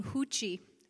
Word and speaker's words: Ruth: Uh, Ruth here on the on the screen here to the left, um, and in Portuguese you pronounce her name Huchi, but uh, Ruth is the Canadian version of that uh, clearly.